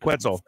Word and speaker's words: Quetzal: Quetzal. Like pretzel? Quetzal.